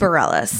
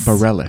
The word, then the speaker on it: Bareilles: Bareilles. – Bareilles.